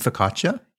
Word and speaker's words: focaccia: They have some house-made focaccia. Did I pronounce that correctly? – You mean focaccia? – Yeah. – I think that's how I would say it.